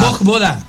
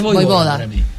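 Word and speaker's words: Vojvoda: Porque es como Djokovic. Es Bojboda.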